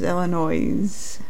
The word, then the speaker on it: Illinois: Illinois, all right, my old hometown, Illinois, "Please don't pronounce the S." Don't you just, it's like running your fingers across the chalkboard when somebody says Illinois. But it's Illinois.